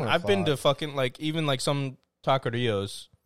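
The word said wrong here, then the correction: taquerías: mm-hmm. or taquerias, I should say.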